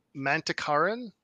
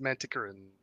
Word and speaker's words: Manticoran: Yeah, so no, David sent it as a pronunciation guide to the narrator and said it is pronounced Manticoran.